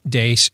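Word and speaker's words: Deace: You can also follow me on Twitter at Steve Dace Show.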